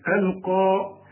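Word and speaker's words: ألقى: وخد بالك مرسومه ياء لكن بتنطق بالالف، القى معاذيره،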